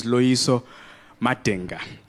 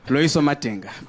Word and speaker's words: Loiso Madenga: Yes, yes, my name is Loiso Madenga, which they mispronounce when I perform in New York. Simple African name, no sounds, no one has never heard in that word before. Loiso Madenga, but they call me Loiso Mandingo.